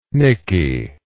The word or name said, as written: nicky